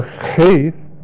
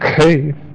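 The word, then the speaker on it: heth: This is the letter heith. It's not pronounced heith, but it's pronounced kaith.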